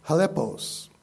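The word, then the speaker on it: chalepos: The word perilous here comes from the Greek word halepos. That's spelled with a C, C-H-A-L-E-P-O-S. Halepos.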